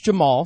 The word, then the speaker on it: Jemarl: Now, his first name is spelled J-E-M-A-R-L, but that's pronounced Jamal, so we'll go with that.